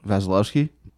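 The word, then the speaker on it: Vasilevskiy: Can you pronounce the Lightning's goalie's name? – Vasilevsky. – That was it.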